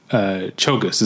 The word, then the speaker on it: Czolgosz: Chogus is how it's pronounced. It's spelled. Okay. It's like C-Z-O-L-G-O-S-Z, something like that.